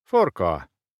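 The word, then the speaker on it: forcola: «Форкола» — уключено «форкоа».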